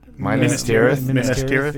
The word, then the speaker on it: Minas Tirith: It doesn't look like Minas Tirith or however you pronounce it. – Minas Tirith.